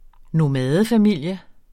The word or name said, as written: nomadefamilie